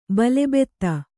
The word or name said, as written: ಬಲಭೇದಿ